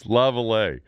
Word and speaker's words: Laviolette: Peter Laviolette. – Yes, Laviolette. – I prefer to use the French Canadian pronunciation. – I know you do, but he's not French Canadian. – Yes, but basically, when in Rome, isn't he from like Jersey?